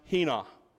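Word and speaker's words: hina (ἵνα): Because there are three clauses here that begin with the word, that begin with a word in the Greek pronounced hina.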